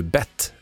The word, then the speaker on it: Bette: Stavas likadant men uttalas ju Bette Midler, som vi hörde här i P4 Östergötland.